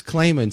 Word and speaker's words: Claman: And Liz Claman sat down and had both of them on her show. Did I say that right? – Yeah, you did. You do it very well, her name.